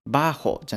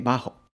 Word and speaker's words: bajo: ですが、まあ、前置詞なのでアクセントがつかないので、バーホじゃなくてバーホ。